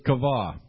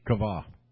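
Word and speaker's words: kavah (קוה): The Hebrew word is kavah. It's spelled Q-A-V-A-H, kavah.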